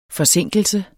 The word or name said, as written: FORSINKELSEN